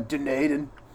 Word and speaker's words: Dunedin: It's a Kiwi guy going, Dunedin. – Oh, okay. Dunedin.